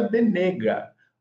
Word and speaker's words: Venegas: Então, quando migrou da Espanha para Portugal, houve um erro de tradução, era Venegas, V-E-N-E-G-A-S. E quando teve esse erro, virou Benega. Né? Mas se a gente fosse levar um pouquinho mais próximo da pronúncia certa do nome, seria Benega, né? Ou Benega, né? Dependendo aí da região.